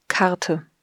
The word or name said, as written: Karte